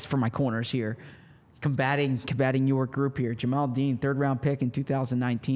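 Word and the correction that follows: Jamel Dean: I believe it is Jamel.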